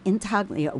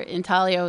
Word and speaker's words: intaglio: What's with the word intaglio, which is spelled, by the way, I-N-T-A-G-L-I-O? – Oh. – Good. But you don't pronounce the G, right? – No, it's Italian.